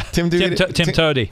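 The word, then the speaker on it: TIMTOWTDI: How do you pronounce that? Tim Twatwati. – Yeah. Tim Toady.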